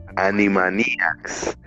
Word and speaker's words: Animaniacs: Se me olvidó pronunciar, ayúdame ahí, hombre Animaniacs.